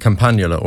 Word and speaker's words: Campanula: And Campanula, is that how you say it? – Campanula or Campanula. It's mm-hmm. all essentially the same,